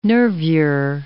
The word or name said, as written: nervure